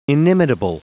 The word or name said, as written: inimitable